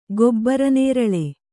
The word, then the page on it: ಗೊಬ್ರನೇರಳೆ: gobra nēraḷe